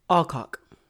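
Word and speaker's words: Allcock: So it's spelled like A-L-L cock, C-O-C-K. Is that how you would pronounce that? Alcock or Alcock? – Alcock. – Let's go with Alcock.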